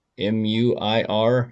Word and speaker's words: Muir: I never did before I started researching this, but kind of John Muir, M U I R, not 100% how to pronounce that, Muir, I think.